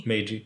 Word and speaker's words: Meiji: Meiji? – Yeah. – Mm-hmm. – Meiji.